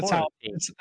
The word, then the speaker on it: Corentin: Is that Corentin? That's how I would pronounce it. In the English language, I don't know if that's actually how it's correctly pronounced in French. – So it's like quarantine.